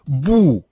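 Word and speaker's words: бу (不): Обычно отрицание «бу» произносится, как вы слышите, именно с четвертым тоном. Но перед глаголом, который также имеет четвертый тон, это самое «бу» меняет свой тон на второй.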